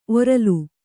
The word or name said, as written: ಒರಲು